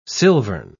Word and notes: silvern: British English

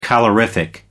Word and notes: calorific: American English

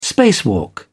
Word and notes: spacewalk: British English

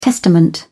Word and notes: testament: British English

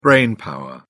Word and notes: brainpower: British English

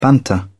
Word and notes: banter: British English

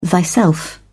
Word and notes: thyself: British English

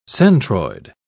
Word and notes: centroid: British English

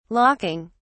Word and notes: locking: British English

